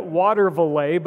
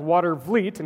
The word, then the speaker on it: Watervliet: We kept pronouncing it Watervliet, but I think we were so tired that we didn't see the order of the letters in the word. It's actually pronounced Watervliet, in case you ever need to know this.